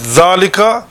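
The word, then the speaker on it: dhalika (ذلك): So dhalika is pronounced as if it were written with an alif. So pronunciation is like that, but written without the alif, but with a long fatha on top of dhal.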